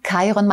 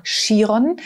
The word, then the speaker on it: Chiron: Chiron, manche nennen ihn auch Chiron. Das ist das Gleiche. Chiron schreibt man mit CH. C-H-I-R-O-N, Chiron.